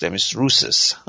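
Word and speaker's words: دمیس روسس (Ντέμης Ρούσσος): البته تو ایران ما میگفتیمش روسس. مثلا تلفظ درستش اون نیست، دمیس روسس اسمشه.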